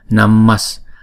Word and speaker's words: Namas: Thì do đó khi phát âm chúng ta phải chuyển chữ Visarga này thành chữ S luôn. Thì chúng ta đọc là Namas, Namasamanta, Namasamanta Buddhanam.